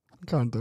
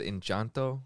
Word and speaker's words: Encanto: Encanto, yeah. You didn't have to say it like you're Hispanic. Encanto. – I almost said Enchanto, but then I changed it, and then the accent just came naturally.